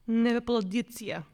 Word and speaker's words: Nebepaleidzia: Nebepaladidzia. – Spell it out for me. – N-E-B-E-P-A-L... E-I-D-Z-I-A. – Nebepaladidzia.